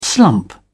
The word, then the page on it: slump: British English